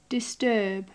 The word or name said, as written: disturb